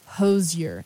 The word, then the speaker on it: Hozier: But he was like, how do you pronounce it? Like, once and for all, you know, let's cut the rumors. Why do you say it? He goes, Hozier.